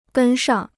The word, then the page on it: 跟上: gēn shàng